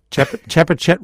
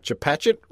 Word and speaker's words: Chepachet: Chepachet, Rhode Island. – Chepachet, Rhode Island.